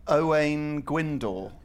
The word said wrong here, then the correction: Owain Glyndŵr: it's Owain Glyndŵr. – I'm going to give a point because he's giving the Bristolians pronunciation of that contentious name.